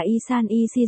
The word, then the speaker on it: 1314: 1314 đọc là Y San Y Si giống với Y Sân Y Si nghĩa là chọn đời chọn kiếp.